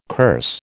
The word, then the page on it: curse: American English